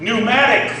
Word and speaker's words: pneumatic: Pneuma is the word that we get the English word pneumatic from. We in the English language don't pronounce the P, so instead of pneumatic, it's pneumatic.